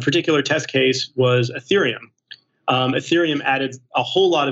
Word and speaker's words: Ethereum: Ethereum is the name of the network, yeah, and Ether is the name of the token.